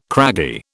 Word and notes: craggy: British English

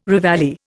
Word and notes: reveille: Indian

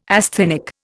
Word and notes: asthenic: Indian

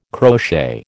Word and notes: crochet: American English

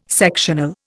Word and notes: sectional: Indian

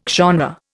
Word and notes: genre: Indian

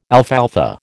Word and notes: alfalfa: American English

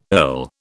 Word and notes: earl: British English

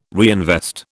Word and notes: reinvest: British English

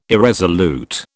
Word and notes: irresolute: British English

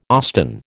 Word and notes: Austin: American English

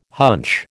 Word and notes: hunch: American English

